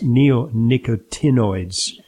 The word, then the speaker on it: neonicotinoids: And in fact, European countries have limited the use of certain neonicotinamides. I'm mispronouncing it. It's neonicotinoids.